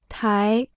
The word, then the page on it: 台: tái